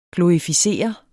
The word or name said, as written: glorificere